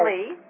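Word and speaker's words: Li: And there is a gentleman that's in charge of this, Robin Lai. – Robin Lee. – Lee, excuse me, I mispronounced that.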